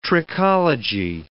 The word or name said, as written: trichology